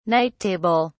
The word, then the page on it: night table: British English